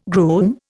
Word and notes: groom: Indian

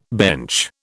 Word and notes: bench: British English

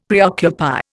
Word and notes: preoccupy: Indian

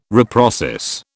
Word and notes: reprocess: British English

